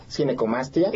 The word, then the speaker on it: ginecomastia: Pues muchas gracias, y sí, definitivamente creo que es una, para empezar la palabra, le decía, ¿cómo se pronuncia? Ginecomastia. – Sí, exacto. Ginecomastia.